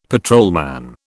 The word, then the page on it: patrolman: British English